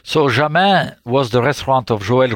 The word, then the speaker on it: Jamin: Jamin, yes.